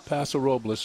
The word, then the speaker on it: Paso Robles: It's not Paso Robles, folks. It's Paso Robles. Even though it's spelled like Paso Robles, we understand that, but that's not the way it's necessarily pronounced by those that know, put it that way.